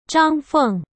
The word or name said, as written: Zhang Feng